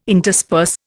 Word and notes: intersperse: Indian

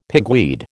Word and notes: pigweed: American English

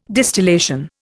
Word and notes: distillation: Indian

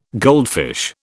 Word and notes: goldfish: British English

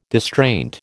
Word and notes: distraint: American English

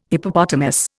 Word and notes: Hippopotamus: Indian

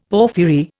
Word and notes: porphyry: Indian

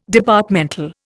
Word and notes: Departmental: Indian

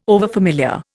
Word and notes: overfamiliar: Indian

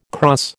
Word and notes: krans: American English

